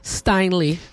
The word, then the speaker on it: Steinle: Steinle. – Steinle would be alive today if it hadn't been for the illegal who shot and killed.